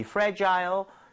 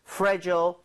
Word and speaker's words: fragile: 다시 한번 이 fragile, fragile 둘다 발음 괜찮습니다. Fragile은 조금 더 미국에 가깝고 fragile 영국에 가깝지만 요즘에는 다 섞어서 쓰는 편이에요.